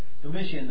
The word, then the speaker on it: Domitian: Domitian, the Roman emperor who demanded worship as God and instigated the second intense period of Christian persecution.